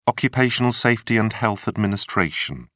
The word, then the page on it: occupational safety and health administration: British English